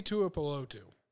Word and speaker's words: Tuipulotu: Tui Tuipulotu. That's how you pronounce it, yeah.